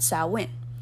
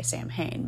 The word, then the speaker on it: Samhain: So Halloween was actually once an ancient Celtic festival called Samhain, which most people nowadays mispronounce it and say Samhain, but, you know, no judgment.